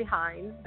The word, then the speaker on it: Hinds: It's Audrey Hinds. The last name is spelled H-I-N-D, like David S.